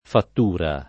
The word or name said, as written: fattura